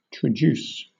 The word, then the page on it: traduce: British English